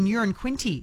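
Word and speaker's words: Uranquinty: It was Tambourine Bay Road, and I love this because Peter's texted in saying, I live in Urin Quinty. It's the same pronunciation as a number one, so it's not a big town. One could describe it as a wee place, and then a laughing emoji.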